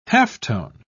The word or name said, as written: halftone